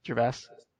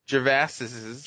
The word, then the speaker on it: Gervais: This is among among uh how do you say his name again? Gervais. – Gervais's Gervais. Gervais's more searing insults during the annual Hollywood Kudo Fest.